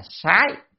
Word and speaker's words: sái: Chữ sát này ở đây có nghĩa là phải đọc âm là sái, chứ không đọc sát là sai. Vừa rồi thì ra đọc sát đúng rồi, nó là theo thói quen. Thế là tra từ điển cho thấy âm đọc là sái, sái là sai biệt.